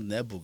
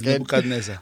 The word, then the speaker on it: Nebuchadnezzar: No, not Nebu, guy. Put in some work. – He can't say it. He can't pronounce it. – It's Nebuchadnezzar.